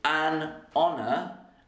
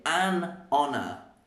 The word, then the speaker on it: honour: So we do in fact say an honour, and that is because the H is silent.